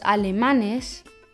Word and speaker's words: alemanes: Aber los alemanes, das steht im Plural, die Betonung ist in der vorletzten Silbe und es endet in Konsonanten und deshalb bekommt es kein Betonungszeichen.